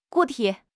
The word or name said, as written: 固体